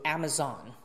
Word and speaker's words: Amazon: OK，Amazon，注意读好这个发音，Amazon。英式读是 Amazon。美式读 Amazon， OK。